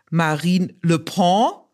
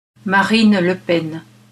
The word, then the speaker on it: Marine Le Pen: In der sprachen Robin und ich über Adis Weidels Reise nach Paris, wo sie sich mit einer Politikerin traf, deren Namen weder Robin noch ich richtig ausgesprochen haben. Robin sagte Marine Le Pen und ich Marine Le Pen. Wie uns einige Hörerinnen und Hörer wissen ließen, ist die korrekte Aussprache allerdings so. Marine Le Pen.